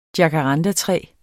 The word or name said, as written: jacaranda